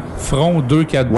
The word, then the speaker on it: Front 242: Alors, Front 242, et oui, ça se prononce vraiment Front 242, ouais. Et non Front 242 ou Front 242, c'est Front 242.